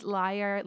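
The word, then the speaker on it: Lyre: It is Liar, like the instrument, not like Pants on Fire, even though sonically sounds the same.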